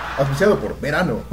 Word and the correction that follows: Visano: o Besano. – No, Visano.